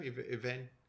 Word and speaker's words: Evangeline: Evangeline. – Oh, Evangeline.